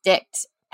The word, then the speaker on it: dighte: Actually used the term, or he used the verb D-I-G-H-T-E, pronounced dict, as a verb for sex.